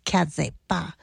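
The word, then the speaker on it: Case Départ: All right, we played Zuki Joseph's Mama Africa, Missy D, French song, Case Depart, but it's pronounced Kaze Ba, and a spoken word piece by Jillian Christmas called Casting.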